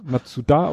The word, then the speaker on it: Matsuda: Und das Mazda Matsuda. Ma Matsuda. Matsuda ausgesprochen.